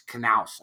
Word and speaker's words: Knaus: I do like it's Knaus. You pronounce the K with Jim. So it's Jim Knaus.